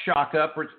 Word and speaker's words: Sciacca: There's no T-H-E in front of it. It's goals book. And it is by last name, pronounced Sciacca, but it's spelled S-C-I-A-C-C-A. It's Italiano.